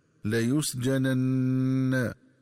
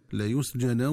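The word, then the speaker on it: ليسجنن: ينبغي التنبه للنطق الصحيح للفظ لا يسجنن، فيقرأ بنونين، النون الأولى مفتوحة مخففة والنون الثانية مفتوحة مشددة هكذا لا يسجنن، ومن الخطأ قراءته بتسكين النون الثانية هكذا لا يسجنا ولا يكون من الصاغرين.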